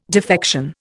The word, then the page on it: defection: Indian